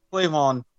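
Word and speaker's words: K'Lavon: K apostrophe L-A-V-O-N. Clavon? – Wait, K apostrophe L-A-V-O-N or L-A-V-E-O-N? – L-A-V-O-N. Clavon? – Yeah, Clavon.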